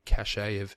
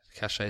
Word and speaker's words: cache: They've got this cachet cachet is not the right pronunciation. Cache. I always get those words mixed up, even though they're spelled the same.